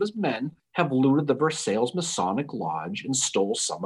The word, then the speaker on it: Versailles: Looks like Versailles, but it's pronounced Versailles.